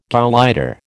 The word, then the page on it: gauleiter: American English